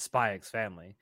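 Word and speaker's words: Spy × Family: One anime that WIT has worked on where the art is certainly not terrible, though, is Spy X Family. And yes, I will pronounce the X because the X is fucking there.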